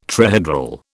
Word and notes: trihedral: British English

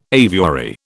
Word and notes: aviary: British English

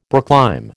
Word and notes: brooklime: American English

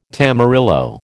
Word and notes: tamarillo: American English

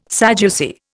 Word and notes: Sadducee: Indian

